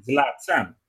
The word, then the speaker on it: Zlatan: Zlatan. That's how you say it.